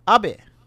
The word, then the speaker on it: Abe: was going to be assassinated, but it was actually Shinzo Abe, another Japanese guy. So what do you think about this? – It's first off, it's pronounced Abe.